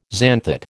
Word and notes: xanthate: American English

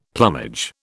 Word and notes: plumage: British English

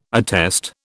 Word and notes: attest: British English

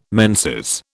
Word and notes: menses: British English

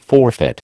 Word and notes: forfeit: American English

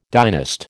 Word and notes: dynast: American English

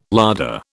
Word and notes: larder: British English

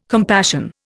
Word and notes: compassion: Indian

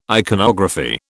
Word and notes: iconography: British English

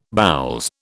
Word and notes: boules: British English

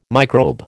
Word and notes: microbe: American English

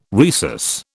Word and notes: rhesus: British English

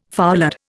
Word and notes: foulard: Indian

